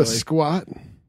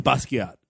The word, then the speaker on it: Basquiat: Basquiat. Is that no? I'm saying it wrong. – Basquiat. – There we go.